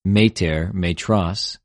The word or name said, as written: μητηρ,μητρος